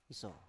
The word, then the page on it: iso: Biak